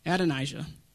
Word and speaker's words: Adonijah: Fourthborn, Andon and Jah. I knew I should have went over this with you. I can't say the name right. Adonijah. Adonijah. My wife even warned me of that.